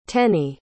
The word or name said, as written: tennie